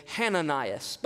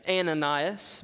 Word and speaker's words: Hananias: You don't see it here in the English, but in the Greek, there's actually a rough breathing mark above the A. And to properly pronounce his name, you would say Hananias. But for our English translation, we'll just say Ananias.